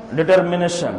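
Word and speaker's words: determination: ایک ہوتا ہے کہ مضبوط ارادہ کر لینا، اس کو کیا کہتے ہیں عربی میں، اس کو کہتے ہیں عزم، انگلش میں کہتے ہیں ڈٹرمنیشن. ہاں، صحیح تلفظ صحیح ادا کر رہے ہیں.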